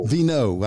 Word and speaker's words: Veinot: Did I say your name properly? – Veinot. – I wasn't even close.